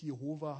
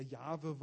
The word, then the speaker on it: Jahwe: Zumal auf Hebräisch ist bloß so ein Vier-Buchstaben-Wort, J-H-W-H. Und man streitet sich bis heute irgendwie, wie es genau ausgesprochen wird. Bin ziemlich sicher, dass es wahrscheinlich nicht Jehova heißt, wie die gleichnamigen Zeugen das immer irgendwie sagen, sondern dass es wahrscheinlich eher Jahwe war, die Aussprache.